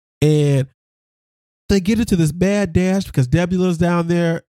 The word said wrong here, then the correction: Nebula: I think it's Debula. – Nebula. – It's Nebula. My bad.